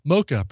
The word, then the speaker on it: Mocha: Mocha, pronounced like the coffee. Oh, it's Mocha.